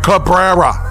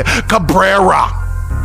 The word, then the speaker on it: Cabrera: I got to get his name right. Cabrera. Let me say it again. Cabrera. There we go. Cabrera. Roll the R's there.